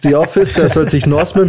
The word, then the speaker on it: Norseman: Äh, also Norseman, äh, das ist wirklich Norse mit N-O-R-S-E, nicht North falsch ausgesprochen.